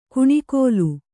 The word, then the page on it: ಕುಣಿಕೋಲು: kuṇikōlu; noun